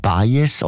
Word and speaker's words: Baez: Sei mit dieser Joan Baez oder Baez? – Baez, gell? Ja. – Wieso? – Latinisch ausgesprochen.